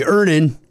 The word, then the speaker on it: Earnin: I'm going to pronounce this properly. Earnin. E-A-R-N-I-N is pronounced Earnin. I don't know how else you would pronounce it, but they really want it clear that that's how to pronounce it.